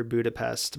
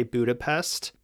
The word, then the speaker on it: Budapest: The first thing I thought I'd start talking about, as far as the pronunciation goes for Budapest, most people you'll meet will say Budapest, but the correct pronunciation is Budapest. So, there's an H in there. That's how the Hungarians pronounce it.